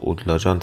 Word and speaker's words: اودلاجان: ولی خب ما بر اساس حالا اسنادی که وجود داره و نقشه ها میدونیم که در همه اونها اودلاجان با این نوشته شده و خب اودلاجان تلفظ میشه.